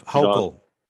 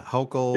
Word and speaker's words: Hochul: But New York Governor Kathy, how do you pronounce her last name? Is it Hochul? – Close enough. Hochul. Yeah, Hochul.